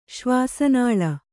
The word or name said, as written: ಶ್ವಾಸನಾಳ